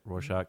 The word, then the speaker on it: Rorschach: Rorschach, mm-hmm.